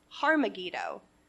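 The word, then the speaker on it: Har Megiddo: And it's pronounced in Hebrew, Har-Megiddo, which means Mount of Megiddo, which sounds like Armageddon.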